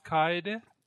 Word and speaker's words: Kaede: Yeah, so first of all, her name is Kaede. – Kaede. Okay. Hi. I just suck at pronunciation. It's you guys should know that by now. Granted, I'm way better than I was when I started all this, but man, I still struggle. Especially when it's like those, like the two, when there's two vowels next to each other, I'm still like, I don't know anyway. – For Japanese, vowels are almost always pronounced separately.